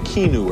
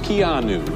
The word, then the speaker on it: Keanu: you for mispronouncing your name. I was saying Keanu or something. It's Keanu.